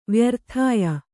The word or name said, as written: ವ್ಯರ್ಥಾಯ